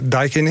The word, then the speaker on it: Daikin: En om ze recht aan te doen, zeg ik even dat het Daikin is.